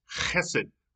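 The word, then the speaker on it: chesed (חֶסֶד): It's spelled Chesed, and it's not chesed, and it's not just hesed, it's chesed, right? I heard a guy say, if you don't spit on your neighbor when you're saying this, you didn't say it right.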